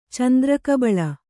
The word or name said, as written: ಚಂದ್ರಕಬಳ